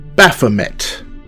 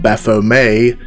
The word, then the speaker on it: Baphomet: So let's crack right on into it, and I'll see you on the other side. Baphomet. It's likely that you've heard of Baphomet by now, or perhaps under the alternative pronunciation, Baphomet, but if you haven't heard the name, then you've almost certainly seen the imagery.